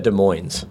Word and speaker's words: Des Moines: Well, said Des Moines. But if the S wasn't silent on Moines, then it wouldn't be silent on De.